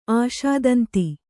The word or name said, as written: ಆಶಾದಂತಿ